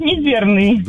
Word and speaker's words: мизерный: А тут еще появился Орлов и предлагает вариант «мизерный». – Ну да, да, не просто ж так. – Нет, пожалуй, я воздержусь и свое мнение отстаю, что это будет слово «мизерный». Ударение на первый слог.